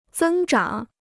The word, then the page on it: 增长: zēng zhǎng